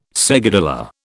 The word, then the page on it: seguidilla: British English